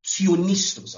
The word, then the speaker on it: theopneustos: It's the word theonistos. I'll spell it for you. T-H-E-O. T-H-E-O-P-N-E-U-S-T-O-S. I'll say it one more time. T-H-E-O-P-N-E-U-S-T-O-S. Theonistos.